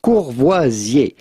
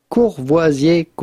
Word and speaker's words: Courvoisier: So how do you go about pronouncing the name of this famous brand with a typical French pronunciation? Courvoisier. Courvoisier cognac.